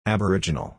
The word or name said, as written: aboriginal